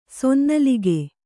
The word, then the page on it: ಸೊನ್ನಲಿಗೆ: sonnalige